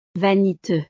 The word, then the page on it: vaniteux: frances